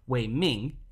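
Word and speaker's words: Wei Ming (嵬名): Instead, he took up a traditional Tangut surname, which is pronounced, I believe, as Nui Mi in the Tangut language itself, but is pronounced as Wei Ming in Chinese, with his full name transliterated as Wei Ming Nang Xiao, though he typically not be referred to by this name.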